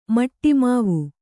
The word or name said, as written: ಮಿಡಿಮಾವು